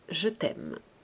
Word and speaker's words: Chatham: C'était le temps où Chatham se prononçait « Je t'aime ».